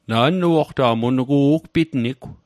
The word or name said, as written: ugpitnit